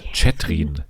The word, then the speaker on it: Chethrin: auch genannt Chatrin von den ganzen ModeratorInnen bei Promi Big Brother. Aber den schreibt man so ganz komisch, man schreibt ja Catherine C-H-E-T-H-R-I-N. Also ganz merkwürdig.